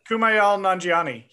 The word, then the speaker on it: Kumail Nanjiani: So one of the guys, his name is Kumail Nanjiani. I think that's how you say it. – Kumail Nanjiani. Yeah.